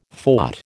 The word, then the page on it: fought: American English